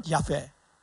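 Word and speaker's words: 야훼 (יהוה): 아, 아까 히브리어 발음을 좀 안 알려드렸는데 자 이거 보면은 요테 와회 이거는 여호와라고 하지만 야페라고 읽어요. 정확한 발음은 여호와는 나중에 독일로 가는 거면서 제호바라고 돼 있어요. 제호바. 제호바라는 독일 발음이 영어식으로 발음하니까 여호와가 된 거야. 스펠링이 그렇게 된 거예요. 자 이거는 뭐냐면 야페예요. 그래서 원래 더 정확한 발음은 야훼. 이게 야훼라는 발음이 원래는 더 정확한 발음이고 예수님이라는 발음은 원래는 예수아.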